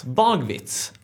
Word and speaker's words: Bagewitz: Jag vet inte om jag uttalar rätt. Nej, Bagewitz.